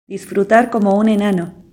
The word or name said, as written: disfrutar como un enano